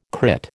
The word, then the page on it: crit: American English